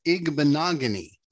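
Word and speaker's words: Igbinoghene: Noah Igbinohene. – Still no. – But I appreciate the confidence. – You nailed the Noah. It is Noah Igbinogany. Igbinogany. It's all hard syllables in there.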